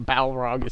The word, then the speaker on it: Balrog: The Balrog is popping out now, and its name is Coral Mayhem.